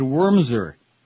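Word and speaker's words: Wurmser: Another possibility is that two lower-level officials in Cheney's office, John Hanna or David Wormser, rhymes with Mumser, leaked Plame's identity at the behest of higher-ups to keep their fingerprints off the crime, Wilson speculated.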